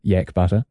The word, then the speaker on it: yak butter: Yeah, now Cam, in the control room, our pronunciation guru, looked up sharply when I said yak butter, and then started nodding, like, yeah. And you are correct.